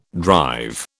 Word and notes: drive: British English

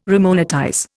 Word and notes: remonetize: Indian

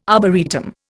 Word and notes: arboretum: Indian

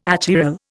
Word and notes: achiral: Indian

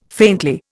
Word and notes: faintly: Indian

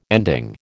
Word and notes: ending: American English

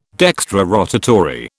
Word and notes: dextrorotatory: British English

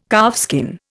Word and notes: calfskin: Indian

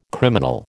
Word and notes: criminal: American English

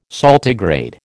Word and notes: saltigrade: American English

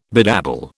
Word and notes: bedabble: British English

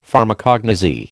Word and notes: Pharmacognosy: American English

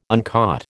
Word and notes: uncaught: American English

